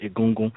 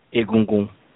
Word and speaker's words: Egungun: And as I said it now, as I was telling you, said how you pronounce it, Egungun, it's like, yeah, that's what we say, but I also understand there's another pronunciation, Egungun. So it's kind of like, Egungun is kind of like, I don't know, it goes up, Egungun.